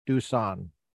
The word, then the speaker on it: Duson: This afternoon in California, we have an incredibly special guest, Monique Duson.